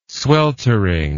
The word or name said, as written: sweltering